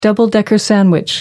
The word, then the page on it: double-decker: American English